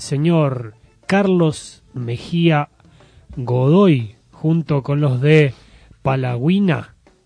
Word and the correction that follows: Palacagüina: Es así, ¿no? Palacagüina, ahí está mejor.